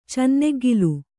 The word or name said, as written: ಚೆನ್ನೆಗ್ಗಿಲು